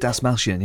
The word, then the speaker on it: Dasmalchian: And also, if Peña Dasmalchian, is that how you pronounce Dave's name? – David Dasmalchian, yes.